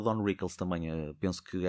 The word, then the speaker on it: Don Rickles: E por isso o Don Wrinkles, ou Don Rickles, que eu tenho a mania de meter um N a mais é, na pronúncia, eh, era um gajo que me enchia as medidas porque ele era assim no dia a dia.